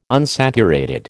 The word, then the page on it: unsaturated: American English